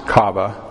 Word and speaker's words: kava: The Hebrew word for patiently is kava, or at least it's pronounced kava.